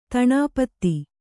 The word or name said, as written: ದಿನಪತಿ